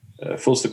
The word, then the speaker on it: Phlster: Filster Concealment Workshop. And it's spelled P-H-L-S-T-E-R.